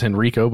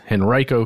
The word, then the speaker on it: Henrico: and that's how you say it, by the way. I thought it was Henrico, but it's Henrico County,